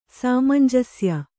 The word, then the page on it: ಸಾಮಂಜಸ್ಯ: sāmanjasya